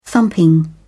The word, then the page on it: thumping: British English